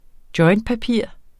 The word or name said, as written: jointpapir